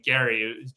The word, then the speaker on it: Gerry: It was the then governor of Massachusetts, Eldridge Gerry, he pronounced his name Gerry, G-E-R-R-Y, and the salamander of the shape of one of the districts in Massachusetts.